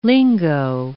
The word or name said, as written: lingo